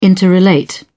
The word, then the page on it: interrelate: British English